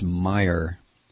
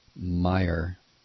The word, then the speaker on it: Meijer: I pronounce it Majors. It's Meijer. Meijer.